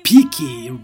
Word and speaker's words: Pique: And Pique rhymes with the word Fique to stay.